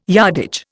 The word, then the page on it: yardage: Indian